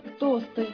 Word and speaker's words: тосты: Но произносить нужно тосты. – Не только тамада должен знать, что правильно говорить тосты, тостов. – В этом слове ударение никогда не меняется.